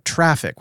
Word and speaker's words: Traefik: He asked if either of us have ever tried out Traffic, which I think is how you pronounce it. It's spelled T-R-A-E-F-I-K.